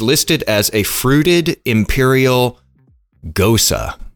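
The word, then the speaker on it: Gose: G-O-S-E. I had to look that up. I did not know. I would have never guessed gosa. – Are you sure that's how you pronounce it? – Gosa. Gosa. Like, it's like Rosa. Gosa.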